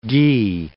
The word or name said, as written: ghee